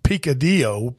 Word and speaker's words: picadillo: And one night they were cooking dinner and they wanted to cook something called, I think, let me make sure I pronounce this, picadillo, P-I-C-A-D-I-L-L-O, which is a Hispanic dish that uses ground beef.